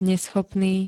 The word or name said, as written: neschopný